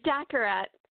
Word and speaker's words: Daccarett: Daccarett.